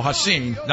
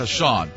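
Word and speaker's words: Ha'Sean: It's pronounced, Hashin, not Hashan.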